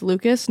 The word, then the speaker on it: l.ucas: Also, his name is pronounced Lucas, not l.ucas.